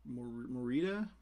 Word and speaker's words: Morita: And you have Pat, how do you pronounce his last name? Pat Morita? – Yes. – Morita, I think that's how you pronounce it.